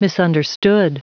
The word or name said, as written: misunderstood